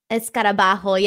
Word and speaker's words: escarabajo: Escarabajo. Yeah, like the hole, like the H. The J is someone pronounced like an H. Ho.